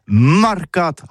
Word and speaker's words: marcat: Eh bien, d'ici à tout, le mercat de l'Uni ou le mercat des rebelles, mais en gascogne toulousain, c'est prononcé les marcat, avec un A marquette.